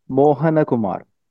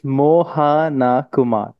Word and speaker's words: Mohanakumar: But yeah, it's Mohanakumar. Mohanakumar. That's my last name. And I think some of those syllables are not present in the English language, certain O sounds and all that.